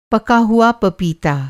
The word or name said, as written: पका हुआ